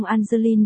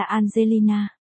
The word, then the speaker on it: Angeline: Cô nói, ở nơi tôi sinh ra e trong Angeline được phát âm là chữ A, ông ta luôn gọi tôi là Angelina.